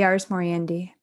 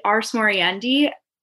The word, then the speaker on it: ars moriendi: And going on to what you talk about throughout the book, I hope I'm saying it right, the ars moriendi. – Yeah, the ars moriendi. – The ars moriendi.